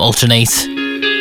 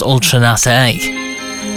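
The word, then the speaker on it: Ultra Naté: I used to call them Ultranate, but it's pronounced Ultranate.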